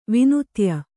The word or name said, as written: ವಿನುತ್ಯ